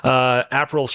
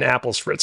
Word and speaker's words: Aperol: Aperol spritz, which I, by the way, okay, for the record, I mispronounced as an apple spritz because I thought it was apple flavored.